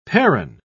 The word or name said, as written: perron